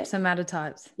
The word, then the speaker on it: somatotypes: Is that how you say it? – Yep, somatotypes.